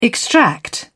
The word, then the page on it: extract: British English